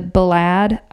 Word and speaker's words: Balad: And she was assigned to the 129th Corps Support Battalion and was sent to Balad, Iraq, and would work in the communications building. And no, I did not pronounce that wrong.